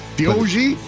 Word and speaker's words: Deohg: D-O-G? – D-O-G! Somebody named their dog D-O... Well, it's spelled D-E-O-H-G, Dioji? – Yeah. – Diogi? – Sure.